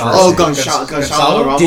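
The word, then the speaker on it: Gonçalo Ramos: Goncalo Ramos.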